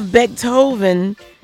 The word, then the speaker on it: Becton: So you have Becton Music Group.